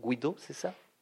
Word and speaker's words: Guido: Je ne sais pas si je prononce comme il, comme euh, il le faut. Guido, c'est ça Non, c'est bien, n'est pas le dernier. – Pardon Vous prononcez bien, mais c'est pas le dernier.